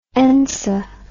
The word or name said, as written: ensa